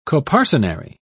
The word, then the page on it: coparcenary: British English